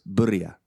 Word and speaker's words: birria: And if you read it, there's no A in the beginning of that word. It's an I. So it's birria.